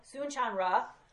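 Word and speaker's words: Soong-Chan Rah: Soon-Chan Ra writes a lot about this in his Prophetic Lamentations book.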